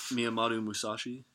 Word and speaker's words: Miyamoto Musashi: Yeah, but the gentleman's name's Miyamoto Musashi. I don't know. – Musashi. – Right there. I don't know how to fucking pronounce that. No offense. Sorry. – Miyamoto Musashi.